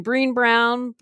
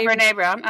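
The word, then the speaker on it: Brené Brown: Like, is it how do you say her name? Breen Brown? – Brene? Oh, yeah, Brene Brown. Uh-huh.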